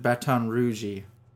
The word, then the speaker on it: Baton Rouge: Is that how they... Baton Rouge? – Yes, they pronounce it Baton Rouge.